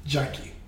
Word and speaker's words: Gyakie: Her name is Gyaki, and the song is called Forever. – Wait. – Or is it Gyaki? – I think it might be Jackie. – Oh, it's spelled... G-Y-A-K-I-E. – Jackie. Okay. Her actual name is Jackie, but like...